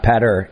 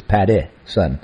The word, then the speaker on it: Pattison: Patterson is spelled P-A-T-T-I-S-O-N, folks, not Patter, it's Patty, son.